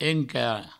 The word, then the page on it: encar: enca